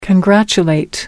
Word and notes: congratulate: American English

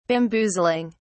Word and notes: bamboozling: British English

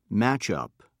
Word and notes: matchup: British English

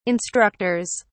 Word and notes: instructors: British English

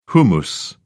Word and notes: hummus: British English

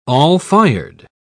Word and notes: all-fired: British English

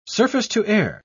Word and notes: surface-to-air: British English

